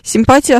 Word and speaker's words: симпатия: А симпатия так и остается, или теперь симпатия, правильно говорить? Нет, симпатия остается именно так.